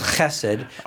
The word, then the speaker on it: chesed: The word is pronounced chesed, but you kind of spit when you say chesed yeah.